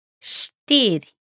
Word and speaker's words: Știri: It's pronounced like a sh sound in show. Listen to this pronunciation. – Știri. Știri. – Which means news.